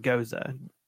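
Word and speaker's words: Gose: I try not to be pretentious because it's pronounced Goza. It's G-O-S-E.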